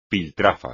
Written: piltrafa

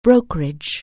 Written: brokerage

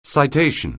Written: citation

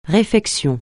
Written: réfection

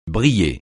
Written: briller

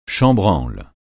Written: chambranle

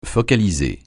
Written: focaliser

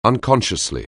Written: unconsciously

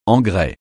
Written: engrais

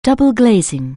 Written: double-glazing

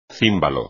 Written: címbalo

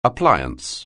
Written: appliance

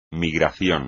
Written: migración

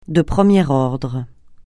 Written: de premier ordre

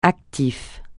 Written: actif

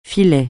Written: filet

